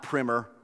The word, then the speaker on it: primer: Not primer. That just doesn't sound right. It's primer. That's how you pronounce it.